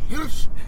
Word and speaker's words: Hirsch: Hirsch! Ähm, das muss deutsch ausgesprochen werden, das ähm, ist ein Hirsch! Das ist ein stolzes Tier!